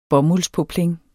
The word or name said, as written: bomuldspoplin